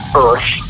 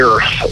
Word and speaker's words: Urth: It's uh, everyone says Earth because that's what it looks like, but it's actually Earth because uh, me and the other guy in the band we were making jokes about urethras.